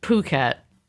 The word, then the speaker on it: Phuket: Spelled P-H, but it's pronounced Phuket. – Phuket, so no H, okay.